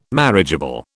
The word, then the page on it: marriageable: British English